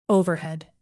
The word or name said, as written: overhead